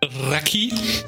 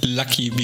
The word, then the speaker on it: Lucky: Äh, es wird Raki ausgesprochen, sehr wahrscheinlich, aber äh, ausgesprochen, wie wir es lesen würden, Lucky, wie glücklich, er hat Glück man hat Glück. L-U-C-K-Y.